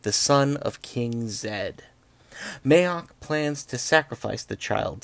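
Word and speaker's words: Maax: And in that kingdom, there is a scheming high priest who goes by the name of Mayok. I'm going to have to do a sidebar here. Mayok is spelled M-A-A-X. I have heard many, many, many people mispronounce it and call it Max.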